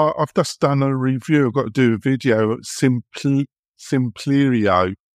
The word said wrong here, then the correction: Simplero: S-I-M-P-I-E-R-O. – Simplero. – Simplero. How do you pronounce it? – I think it's called Simplero. – Yeah, Simplero.